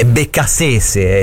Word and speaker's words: Beccacece: Ieri lo chiamavamo Beccacece, ma questa è una pronuncia da fucecchio, no? È Beccacece, è il CT.